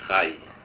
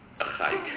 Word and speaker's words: Rayi: My name is Mandla Khai. I'm the chairperson of the Select Committee on Economic and Business Development. Khai is spelled R-A-Y-I. – Mr. Rai. – Khai.